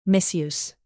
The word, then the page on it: misuse: verb